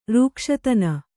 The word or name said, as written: ರೂಕ್ಷತನ